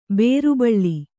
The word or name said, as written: ಬೇರುಬಳ್ಳಿ